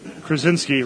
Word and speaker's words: Krasinski: So if we're gonna have someone like John Krasinski, right?